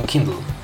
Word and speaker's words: Kindle: Tak, ponieważ powstały wątpliwości, więc postanowiłem sięgnąć do źródła, sprawdziłem na YouTubie, jak wymawiają to Amerykanie, wymawiają Kindle.